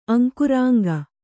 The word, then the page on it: ಅಂಕುರಾಂಗ: Kannada